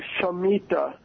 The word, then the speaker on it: Shemitah: It's the Semitah. Is that how you pronounce it? – Yeah, that's how he pronounces it. That's how I pronounce it, the Shemitah. You know, although there's other pronunciations of it, but, you know, you can just call it the year of release or the seventh year rest.